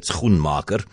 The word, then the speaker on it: Schoenmaker: And I'm pronouncing it Schoenmaker because I asked her father when we spoke just after 3 o'clock, because I've heard so many different pronunciations of the surname. I said, how do you pronounce it? And he said, not S-K, but S-G, because it's of Dutch origin.